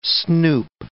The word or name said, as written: snoop